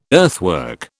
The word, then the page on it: earthwork: British English